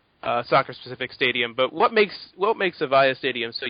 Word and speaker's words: Avaya: Avaya? – Mm-hmm.